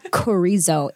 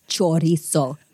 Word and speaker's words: chorizo: It is not chorizo. It's chorizo. Get it right, people.